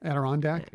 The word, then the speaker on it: Adirondack: Adirondack.